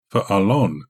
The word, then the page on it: falon: Galindri